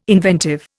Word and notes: inventive: Indian

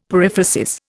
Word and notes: periphrasis: Indian

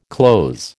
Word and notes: clothes: American English

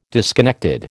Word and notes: disconnected: American English

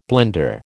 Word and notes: blender: American English

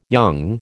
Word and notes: young: American English

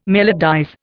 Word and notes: melodize: Indian

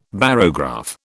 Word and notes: barograph: British English

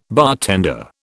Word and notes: bartender: British English

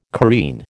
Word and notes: careen: American English

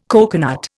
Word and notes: coconut: Indian

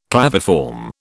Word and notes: claviform: British English

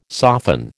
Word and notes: soften: American English

